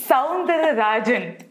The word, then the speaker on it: Soundararajan: Right, Soundararajan. – So they basically no. That is not how you say it. First of all, I just Soundararajan. I got it correct.